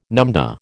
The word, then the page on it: numdah: American English